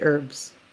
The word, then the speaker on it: herbs: Oh, so Canadians are one of those countries that pronounce the H What? – because we say herbs.